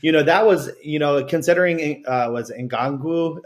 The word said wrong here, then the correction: Nwangwu: he got hurt off the first kickoff. – Nwangwu, bro. – Nwangwu.